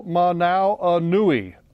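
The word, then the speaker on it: Hoomanawanui: It actually has seven syllables. Uh-oh, Manau Anui.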